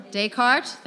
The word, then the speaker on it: Descartes: Oh, I need another pronunciation. Descartes? The philosopher, Descartes, thank you.